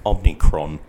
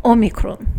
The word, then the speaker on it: Omicron: Omicron. If you want to pronounce it with the Greek accent. – Or if you want to be a pleb like me. – Omicron.